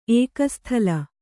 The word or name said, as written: ಏಕಸ್ಥಲ